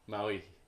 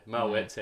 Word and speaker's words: Moët: Moët, yeah. Moët's how they say it, but we say Moët because we're Aussie. – Is it actually Moët? It's French, yeah.